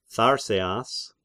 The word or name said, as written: Tharseas